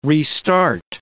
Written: restart

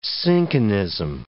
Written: cinchonism

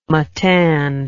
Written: Matane

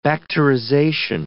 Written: bacterization

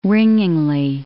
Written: ringingly

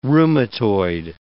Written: rheumatoid

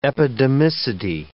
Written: epidemicity

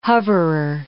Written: hoverer

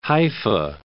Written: Haifa

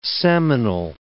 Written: seminal

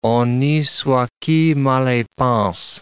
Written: honi soit qui mal y pense

